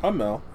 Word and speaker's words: Amell: Stefan Amell's wife, Amell. I'm sorry. I don't know how to say your name, bro. – I'm Mel. You had it right.